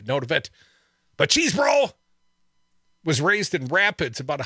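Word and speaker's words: Chesebro: Cheese Bro, pronounced Cheese Bro.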